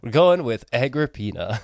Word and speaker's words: Agrippina: I believe we decided it is Agrippina. – Agrippina. – We literally the first time, both of us looked up how to pronounce this name and got four different pronunciations. So we're going with the one that we heard the most.